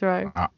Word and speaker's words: Wroe: Is it Wroe? Is that how you pronounce it? It is Wroe. It's Wroe.